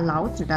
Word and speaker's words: 老子: 好了，今天我们说了老子的两个读音。三声时是哲学家老子先生，轻声时说出来就不太礼貌了，表示我或者爸爸，还是建议大家不要使用了。